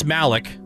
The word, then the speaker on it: Malick: Terrence Malick. Am I saying that right? – Yeah.